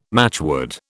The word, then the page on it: matchwood: British English